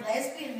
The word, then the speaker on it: lesbian: Lesbian with three syllables.